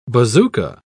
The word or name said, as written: bazooka